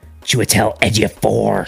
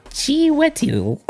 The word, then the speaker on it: Chiwetel Ejiofor: That's not how it's spelled. – I know. I just know it because I've heard people say it. Chiwetel. I'm just like, Chiwetel. – Ejiofor. Okay. I mean, that one's pretty close.